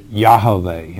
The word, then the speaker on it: Yahweh: The correct pronunciation of the sacred name is Yahweh.